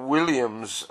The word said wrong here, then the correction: Willian: That's the Brazilian, Willian. Sorry about the pronunciation.